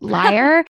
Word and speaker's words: Lyre: but Lyre, L-Y-R-E, like the instrument.